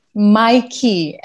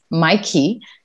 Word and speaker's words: MYKI: It's Mikey, and it's spelled M-Y-K-I because we wanted to be a little hip. So it comes from your key to your digital workspace. That's what it is. It's actually super simple, and it's pronounced Mikey.